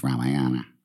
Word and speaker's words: Ramayana: the Ramayana is the story, or the Ramayan, which is the way they pronounce it. In English, it's Ramayana.